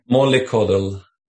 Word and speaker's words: Mollycoddle: Mollycoddle.